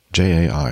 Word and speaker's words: Jai: Lifeguard supervisor Jai Sheridan or Yai? J A I.